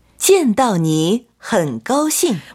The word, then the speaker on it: 见到你很高兴: 见到你很高兴.